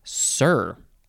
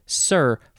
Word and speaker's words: cere: Next we have sir. I think that's just how it's pronounced. It is spelled C-E-R-E, sir, first form.